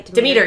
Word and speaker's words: Demeter: Am I saying that right? – Demeter. That's correct.